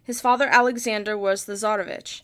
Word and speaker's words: Tsarevich: Also, quick thing, to give you the Russian pronunciation, for Tsarevich, it's Tsarevich.